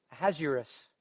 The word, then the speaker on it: Ahasuerus: In the book of Esther, do you remember King Ahasuerus? I don't even know how to say his name. I looked it up this morning in the Hebrew. It's Ahasuerus.